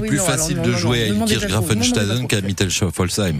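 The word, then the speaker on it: Illkirch-Graffenstaden: Ilkirch Grafenstaden, je le dis, mais vraiment, alors là, mais facile.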